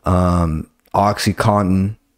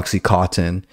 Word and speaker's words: Oxycontin: Oxycontin. That's how you correctly pronounce it. It's not Oxycontin.